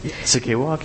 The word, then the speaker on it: Sakewak: I know they probably participate in some of this, but how about the First Nations organizations like Sakewak, is that how you say it? – Sakewak, yeah.